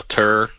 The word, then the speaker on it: Tur: This tour guy, I don't know how much long. Is that how you pronounce it? Tour? T-U-R? – I think so. Tour.